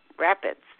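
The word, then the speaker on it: Rock Rapids: in Iowa in Rock Rabbits, uh, Rabbit, I'm not pronouncing that right, Rapids, uh, Iowa, uh, of a young woman who was uh, found and buried as a Jane Doe in 1978.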